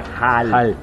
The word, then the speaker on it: Hol: Y los tanagarianos que llegan a lo que vendría siendo el Silver Age es H-O-L. – Ajá. – Hall. – Hall. Ajá. Se pronuncia igual, pero se no diferente. Se escribe diferente.